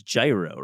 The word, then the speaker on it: gyro: I love that you pronounce it gyro, not gyro, even though you're from Long Island.